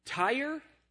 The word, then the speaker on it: Tyre: Tyre and Sidon, S-I-D-O-N, that's the way that we would pronounce it now. T-Y-R-E, Tyre and Sidon, those are the two cities that are being spoken of here.